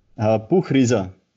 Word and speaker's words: Buchreiser: Buchreiser. It's a little bit hard if you're not German speaker naturally.